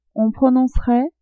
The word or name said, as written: on prononcerait